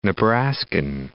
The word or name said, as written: Nebraskan